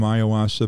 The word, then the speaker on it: ayahuasca: So I've read about Aaron Rodgers' experience with ayahuasca.